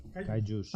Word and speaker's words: kaijus: Por data, el programa de Kaijus, no sé, pues, si mm. se dice así, ¿no? Kaijus. – Kaijus.